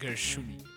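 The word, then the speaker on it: Gershuny: de Theodore Gershuni. A ver, ¿está bien pronunciado? – Gershuni.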